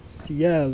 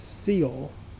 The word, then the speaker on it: ciel: How do I remember that ciel means sky, yet not pronounce ciel as seal, because it is C-I-E-L? First, I recognize ciel is close to the English word ceiling, which is up, like the sky. Next, I can remember the pronunciation by imagining C-L, as in the letters, being written on that ceiling.